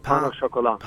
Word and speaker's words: pain au chocolat: Look, since there's no e at the end of chocolate, you don't pronounce the t really. So it's pain au chocolat.